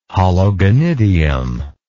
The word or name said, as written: hologonidium